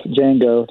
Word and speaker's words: Django: Close enough. Django, the D is silent.